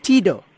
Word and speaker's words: Tyhido: And also Ndithini, before I mangle your surname, how do you pronounce it? – Tyhido Sakin. – Tyhido. Ah, that was easier.